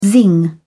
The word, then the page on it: zing: British English